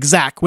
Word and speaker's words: Zach: I'm like, Zach with an H.